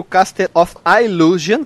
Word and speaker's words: Castle of Illusion: Sendo que o Castle of Illusion, que é a pronúncia certa, ele tem uma jogabilidade bem diferente do Master, é legal.